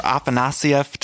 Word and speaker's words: Afanasieff: Okay, guys, I'm here with Walter Afanasieff. Did I say that correctly? – You said it perfectly.